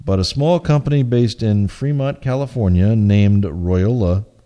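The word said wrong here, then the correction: Royole: Royal, pronounced Royal, it's R-O-Y-A-L-E, is hoping to get close.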